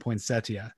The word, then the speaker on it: Poinsettia: That's the proper pronunciation, Poinsettia.